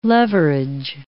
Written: leverage

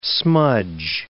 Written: smudge

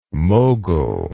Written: Moghul

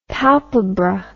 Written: palpebra

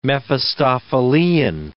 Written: Mephistophelean

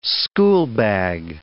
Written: schoolbag

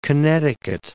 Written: Connecticut

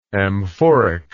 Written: amphoricity

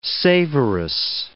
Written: savorous

